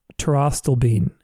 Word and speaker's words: pterostilbene: But Thai ginseng, which is, you know, T-H-A-I, Thai ginseng, like the country, or pterostyl bean. And that's spelled P-T-E-R-O-S-T-I-L-B-E-N-E. Pterostyl bean.